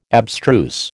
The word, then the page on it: abstruse: American English